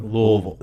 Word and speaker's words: Louisville: You can tell he's a native because he says Louisville. – Louisville. You got to make it sound like you've got marbles in the back of your throat. That's how you say it. Louisville.